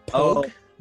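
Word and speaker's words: POG: What is it's a P-O-G pronounced pogue? – A pogue.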